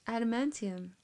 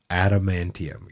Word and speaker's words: Adamantium: Say that right for the Marvel fans, please. – Adamantium. – Adamantium, exactly. You said it differently the first time.